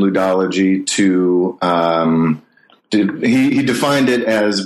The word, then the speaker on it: ludology: Well, that's good because in this book, Cultural Code, you talk about you use a specific methodology, ludology, if I'm pronouncing it correctly.